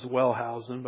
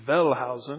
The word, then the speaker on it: Wellhausen: I mean, I pronounce it as Wellhausen, but I think in German it's Wellhausen. Does that sound right?